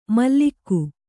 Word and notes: ಮಲ್ಲಿಕ್ಕು: mallikku

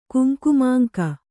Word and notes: ಕುಂಕುಮಾಂಕ: kuŋkumamāŋka

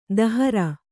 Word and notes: ದಹರ: Kannada

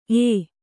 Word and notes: ಏ: ē

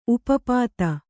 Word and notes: ಉಪಪದ: kannada

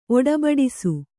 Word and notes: ಒಡಬಡಿಸು: Kannada

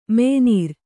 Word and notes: ಮೆಯ್ನೀರ್: Kannada

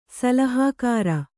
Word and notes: ಸಲಹೆಗಾರ: salahegāra

